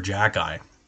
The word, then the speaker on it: Xhekaj: So his last name is spelt X-H-E-K-A-J. Wait, how do you get Jacki out of that? I don't even know. If it's phonetically, it's closer. If you spell it backwards, it looks like it would be closer to Jacki.